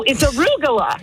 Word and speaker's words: arugula: No, it's arugula. – Right, call him right now. – It's arugula. – Arugula is like Caligula.